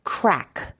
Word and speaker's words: craic: Yeah, it's such an N-I-X-E-R. Yeah. And then a very common one that Americans always get wrong, crack, which is spelled C-R-A-I-C.